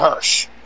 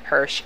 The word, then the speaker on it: Hirsch: How do you say his name? Hirsch? I don't know if I saw your name, but anyway, the winner. – The correct verbiage would be Layla Hirsch. – Hirsch, thank you.